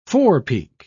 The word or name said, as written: forepeak